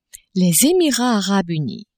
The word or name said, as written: Emirats arabes unis